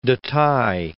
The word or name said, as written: Detaille